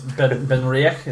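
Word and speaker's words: Benriach: So it's Ben Rioch, is that what I heard? – Yeah. I couldn't resist, because what do I know about pronouncing Scottish words? – And technology, we have it. – Yeah, mm-hmm. It's if you type in pronounce Ben Rioch, there you go.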